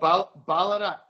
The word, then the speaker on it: Ballarat: So that was called Ballarat, if I pronounced that correctly. – That's right, yeah. Funny accent, but you pronounced it correctly, yeah.